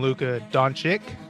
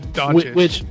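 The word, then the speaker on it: Dončić: Mr. Cage continually throughout the game called him Luka Doncic. So I don't know why he couldn't even look up how to pronounce the probable rookie of the year's last name, Doncic.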